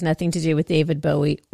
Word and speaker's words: Bowie: Bow? How do you say it? What? – Who Bowie, is it? Dude. Bowie.